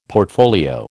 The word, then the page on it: portfolio: American English